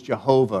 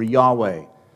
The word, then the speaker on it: Yahweh: We pronounce it in English as Jehovah or Yahweh.